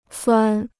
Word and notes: 钻: zuàn

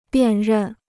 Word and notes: 辨认: biàn rèn

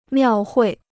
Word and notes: 庙会: miào huì

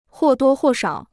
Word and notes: 或多或少: huò duō huò shǎo

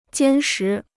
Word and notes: 坚实: jiān shí

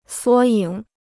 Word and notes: 缩影: suō yǐng

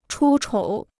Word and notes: 出丑: chū chǒu

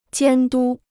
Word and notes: 监督: jiān dū

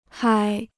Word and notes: 嗨: hāi

